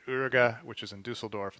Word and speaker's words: Uerige: So the Dusseldorf one is is Uerige. – Uerige.